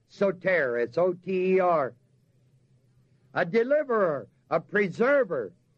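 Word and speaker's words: sōtēr: The word Savior in the text is the word soter, S-O-T-E-R, with a long O and a long E.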